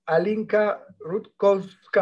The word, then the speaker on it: Alinka Rutkowska: My guest today is Alinka Rutkowska.